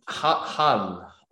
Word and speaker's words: Hull: There's like a big marathon website, and there was a marathon up in Hull. I'm trying to say it correctly. H-U-L-L. Hull. Hull, yes.